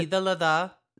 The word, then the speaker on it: Eid al-Adha: Eid al-Adha, how do you pronounce it? – Eid al-Adha.